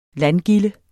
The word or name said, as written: landgilde